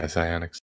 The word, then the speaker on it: Psyonix: Psyonix. Yeah, Psyonix.